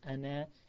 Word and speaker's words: ana: Even though it's written alif, as in a hamza, noon, and it finishes with a alif, so you would think it's pronounced as ana with a long alif at the end, ana, but it's not pronounced ana, it's pronounced ana. Ana, as just a short ana and not ana.